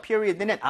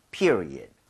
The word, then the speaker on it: period: period는 앞에 e에다 강세를 좀 많이 주시면 좋아요. – 네. – 그래서 같이 한번 해보도록 하겠습니다. period, period, period, period.